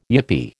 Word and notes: yippee: American English

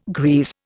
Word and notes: grease: Indian

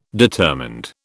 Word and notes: determined: British English

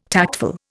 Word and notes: tactful: Indian

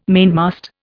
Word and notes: mainmast: Indian

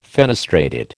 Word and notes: fenestrated: American English

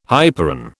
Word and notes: hyperon: British English

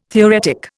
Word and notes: theoretic: Indian

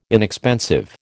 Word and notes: inexpensive: American English

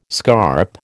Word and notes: scarp: American English

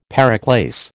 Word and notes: periclase: American English

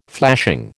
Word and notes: flashing: American English

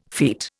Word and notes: feet: Indian

